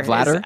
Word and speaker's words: Vladar: It's Vladar. – Vladar? – Well, I'm seeing, yeah, that's how I'm reading the pronunciation. – So it's like batter.